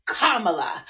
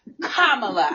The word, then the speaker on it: Kamala: said Kamala, and she said it's Kamala, like comma, Kamala.